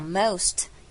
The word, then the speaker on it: most: Because the most beautiful, 好 ,most, 一样把什么省略，把尾音 T 给省略，只要发 most 就好， most beautiful, 这样就不要念成 most beautiful, 这样太 K 了。